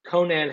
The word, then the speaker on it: Conan: Is it not Conan? – Conan Hayes. – I don't know. – It is.